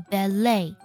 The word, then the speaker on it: ballet: 第三个单词，芭蕾舞，这个单词呢，单纯去看我们的拼写，你会发现 ballet 字母 T 呢，你会本能的把它读出来，但其实啊，这个单词读作 ballet，字母 T 是不发音的 ballet。